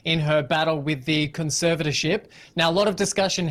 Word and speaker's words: conservatorship: We've been talking a lot about her convertership. I can't even say it. How do you say it? – Conservatorship. – Conservatorship. Thank you very much.